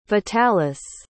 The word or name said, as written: vitalis